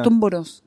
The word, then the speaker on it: Tumburús: ¿Tumburús así se pronuncia? – Tumburús. – Lo dije mal todas las veces. – ¿Cómo le decías? – Tumbur, como, con acento en la. – Ah, bueno, sí, segunda. – El acento va cambiando.